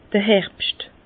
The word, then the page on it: d'r Herbscht: Bas Rhin; Herrlisheim